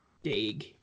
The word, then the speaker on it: Daigle: I hope I'm saying his name right because I never bought it was like D-A-I-G-L-E. – Yeah, I think that's how you pronounced it. That's how everybody pronounced it. – Yeah, I thought it was Daigle back in the day.